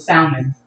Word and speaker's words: salmon: But she's saying a silent letter that's not supposed to be said. That's not supposed to be pronounced in the word. – How do you know? – It's salmon, right? – But it's spelled S-A-L-M-O-N.